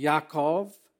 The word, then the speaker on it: Yaakov: You know, Jacob in Hebrew is actually pronounced Yaakov.